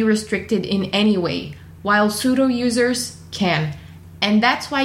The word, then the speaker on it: sudo: And yes, I know it's not pronounced pseudo, but if I pronounced it correctly as simply sudo root user, it would have been kind of confusing. So sorry about the mispronunciation, but I had to get that silent P across.